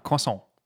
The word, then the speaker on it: croissant: Well, if you say it in the Francophile way, you wouldn't pronounce the T. You'd be like, croissant, right? – In the what way?